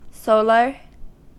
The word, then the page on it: solar: American English